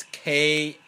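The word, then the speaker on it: Qalo: Got a Kalo, how do you say it? – I'd assume Kalo. – It's Kalo.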